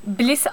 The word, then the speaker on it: Blys: Bliss adlı BLYS diye okunuyor.